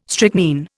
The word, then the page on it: strychnine: Indian